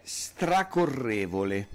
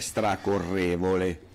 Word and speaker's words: stracorrevole: Allora, una di queste parole, per esempio, è stracorrevole, con Ok. – l'accento sulla E, stracorrevole, ok? – Stracorrevole.